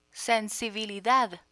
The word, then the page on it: sensibilidad: spanish